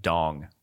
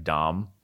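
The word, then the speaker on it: đồng: it's spelled like dong, which is fun, but it's pronounced kind of like dom.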